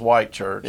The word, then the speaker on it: Whitchurch: Yeah, it was spelled Whitchurch, but it's pronounced White Church.